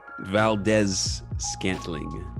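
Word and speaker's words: Valdes-Scantling: Valdez Scantling.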